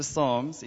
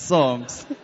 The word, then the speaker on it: Psalms: He says, Jacobus, I just want to tell you that in English, there's a silent P. It's not the Psalms, it's Psalms.